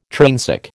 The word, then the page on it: trainsick: American English